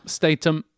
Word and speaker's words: Statham: Ja, Statham. – Statham. Lite så ham, inte ham, utan det är mer så Statham. – Statham.